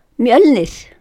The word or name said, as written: Mjöllnir